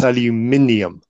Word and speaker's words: aluminium: Um, you do say things uh, that uh, are, I think you probably pronounce aluminum differently than than that I. That is not a word. – What you just said. It is definitely not a word. It's aluminium.